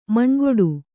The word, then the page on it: ಮನಗೂಡು: managūḍu